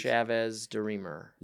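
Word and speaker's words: Chavez-DeRemer: Chavez de Remer.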